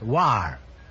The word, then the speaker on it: Loire: I'm sorry, the correct answer is L-O-I-R-E, pronounced war.